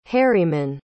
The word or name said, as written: harimann